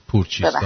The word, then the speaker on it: پورچیستا: اتفاقا گفت این بار درست گفت، تنها تلفظ نام دختر اشو زرتشت رو اشتباه گفت که پورچیستا هست.